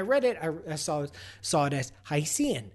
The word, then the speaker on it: Hycean: No, it's supposed to be pronounced Hycean. Hycean. Because Hycean is a portmanteau of hydrogen ocean.